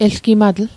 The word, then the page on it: elgimatl: Mi'gmaq